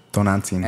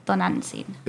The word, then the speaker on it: Tonantzin: Tonantzin. – Ants. – Okay, look, this is how you spell it. Tonantzin. Maybe by seeing it, you'll Tonantzin. – It's like ton of ants in.